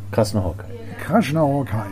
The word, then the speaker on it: Krasznahorkai: Lazio Krasznahorkai. – Krasznahorkai.